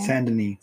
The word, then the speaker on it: Saint-Denis: Saint-Denis. – Saint-Denis. I don't speak French. – I don't either. It's in the same name as in Red Dead Redemption 2. – Okay, so. – Saint-Denis.